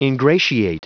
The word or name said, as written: ingratiate